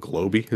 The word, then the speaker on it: Globi: Like, one thing I made once that was really good, they're called, I think they're called Globi is how you pronounce it.